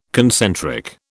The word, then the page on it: concentric: British English